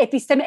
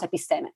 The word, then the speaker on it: episteme: In fact, episteme, and I'm giving the Greek pronunciation, in English would be episteme, but I'm reading in Greek the way it is pronounced, episteme.